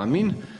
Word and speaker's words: amin: U pravoslavnom svetu, kao što znate, koristi se oblik koji je po grčkom čitanju, koji se po grčkom čitanju izgovara kao amin, a na zapadu se koristi oblik koji se zbog latinskog čitanja grčke ite, odnosno ete, do danas izgovara kao amen u različitim, dakle, jezicima zapadnim.